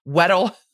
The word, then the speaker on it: Weddle: Nirvana, Universal Music Group, Inc., the David Geffen Company, Geffen Records, Warner Music, MCA Music, Kirk Weedle, the photographer, Weedle, I guess, is the Pokemon. Weddle.